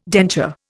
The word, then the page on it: denture: Indian